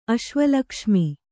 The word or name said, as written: ಅಶ್ವಲಕ್ಷ್ಮಿ